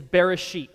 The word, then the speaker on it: Bereshit: The Hebrew title for the book of Genesis is Bereshit.